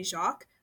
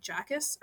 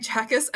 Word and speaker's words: Jacques: In my head, I was always pronouncing it Sophie Jacques, but on the broadcast, they were pronouncing it like Jackus or something, which sounds so wrong to me yeah. as a francophone but I think it's Sophie Chakas, And if it's wrong, if I'm wrong, like please tell me.